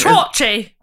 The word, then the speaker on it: Treorchy: Treorchy.